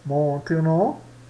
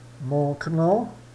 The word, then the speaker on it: maintenant: Maintenant. Então esse aqui também fica mudo, tá? Maintenant.